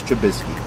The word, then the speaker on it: Trubisky: I do want to see the Bears game because I enjoy watching Mitch Trubisky. Am I saying his name right? – It sounds good to me.